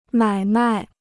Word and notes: 买卖: mǎi mài

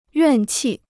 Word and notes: 怨气: yuàn qì

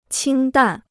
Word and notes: 清淡: qīng dàn

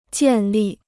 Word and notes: 建立: jiàn lì

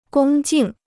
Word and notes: 恭敬: gōng jìng

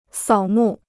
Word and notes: 扫墓: sǎo mù

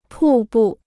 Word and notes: 瀑布: pù bù

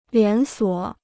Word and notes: 连锁: lián suǒ

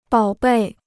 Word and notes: 宝贝: bǎo bèi

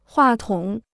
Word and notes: 话筒: huà tǒng

